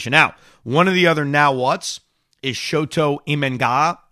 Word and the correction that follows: Shota Imanaga: Uh, let me pronounce his name right. Shota Imanaga, who is another Japanese pitcher.